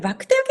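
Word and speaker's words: 肉骨茶: これ、バクテーは結構皆さん聞いたことがあると思うんですけど、肉に骨にチャと書いて、バクテーと言います。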